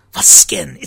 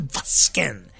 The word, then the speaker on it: Vazgen: It's not Vazgen. It's Vazgen. And actually, all Armenian words have their accent on the last syllable. So it's not Vazgen. It's Vazgen. Yeah, it's always on the last syllable.